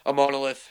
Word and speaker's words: Imonolith: Yeah, Imonolith, yeah.